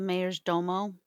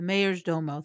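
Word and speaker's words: majordomo: Because obviously the guy that they talked to, you said the Domage, how do you pronounce it? – The Mayor's Domo. – The Mayor's Domo. Thank you.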